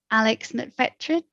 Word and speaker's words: Alex Mcfetridge: Alex Mcfetridge.